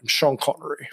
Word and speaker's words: Sean Connery: He played a villain against Sean Connery a couple times. I think he was in. – You can't say it like that. Some Bond. – Sean Connery. – There you go.